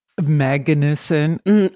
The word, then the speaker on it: Magnuson: Go off on Billy Magnuson. – Magnuson. – I'm just making sure I said it right.